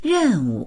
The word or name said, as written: ren4wu